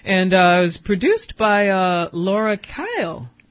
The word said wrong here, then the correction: Kale: Is that, am I pronouncing her name right? – Yeah. – Laura Kyle? – Kale. – Yeah. Kale. Laura Kale.